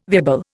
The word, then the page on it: viable: Indian